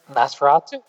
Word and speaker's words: Nosferatu: Um, but also, we had a new pin that Brian created for his store that is the I can never say this correctly, so you guys can correct me at the end. No Farasu? No Farastu? You know what I'm talking about? Nosferatu. There you go.